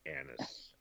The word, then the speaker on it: anise: And if you don't know English too well, that's with a short A, anise.